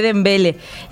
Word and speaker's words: Dembélé: Francia primero con el triplete de Dembélé. En este caso, Noruega segundo. ¿Así se pronuncia? – Dembélé. – Ahí está.